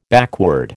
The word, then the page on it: backward: American English